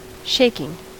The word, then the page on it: shaking: American English